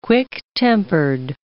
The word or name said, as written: quick-tempered